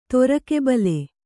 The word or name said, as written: ತೊರಕೆಬಲೆ